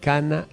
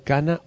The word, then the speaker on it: Cana: Respuesta: en Cana, Líbano. Se pronuncia igual Cana o Cana con K en el Líbano.